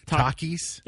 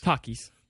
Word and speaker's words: Takis: Takis? – Yep. – T-A-K. Takis? – Takis. – Takis. Yeah, it's all, I don't know. It just looks very, this style, this Takis style, like it doesn't appeal to me.